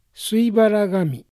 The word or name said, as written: Suibaragami